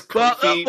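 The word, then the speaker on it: Ko-fi: Isn't it pronounced Kofi? – It's one of the two. It's the site is Kofi, buying a coffee.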